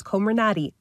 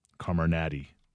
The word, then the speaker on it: Comirnaty: Here are the names properly pronounced. – Pfizer's COVID-19 vaccine will now be known as Comirnaty. Moderna will be called the Spikevax, and AstraZeneca's will be called Vaxzevria. – Comirnaty.